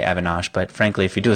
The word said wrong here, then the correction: Evanish: Oh, did I say his name wrong? And I really want to work with the guy even more. Evanesh. I say Evanosh. You're right. It's spelled Evanesh. Jason Evanesh. E-V-A-N-I-S-H.